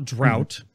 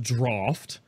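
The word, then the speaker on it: Draught: So the draft of souls, it's not drought, it's pronounced draft.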